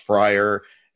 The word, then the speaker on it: Fryer: It had a different spelling, but it was pronounced Fryer. It was F-R-Y-R-E-A-R, but pronounced Fryer. And the Zodiac always talked about cruising on Fry Night. Zodiac would write F-R-Y in all caps, Fry Night, looking for more victims on Fry Night.